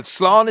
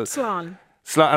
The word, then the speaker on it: Slán: Slán is an easy in Irish. It's just a single syllable. – Slán.